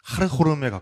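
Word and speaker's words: 하르호름에 (Хархорум): 제가 알기로는 그 카라코람이 이제 현지 발음으로는 하르호름에 가까운데 음.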